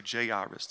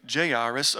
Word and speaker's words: Jairus: Then a man named," there are so many pronunciations of this name, Jairus, I'm going to say Jairus this morning, sounds a little more preachy. "Then a man named Jairus, a ruler of the synagogue, came and fell at Jesus' feet, pleading with him to come to his house."